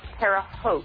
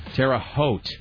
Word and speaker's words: Terre Haute: The town's name is pronounced Terre Haute. – Terre Haute. – Yes, as like, in haute couture or haute cuisine.